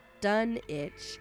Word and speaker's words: Dunwich: Although the English town is pronounced Dunitch, similar to the Rhode Island Greenwich, Lovecraft never specified how he preferred his Dunwich to be pronounced, Dunwich or Dunwich.